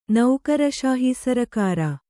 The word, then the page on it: ನೌಕರಶಾಹೀಸರಕಾರ: naukara śahī sarakāra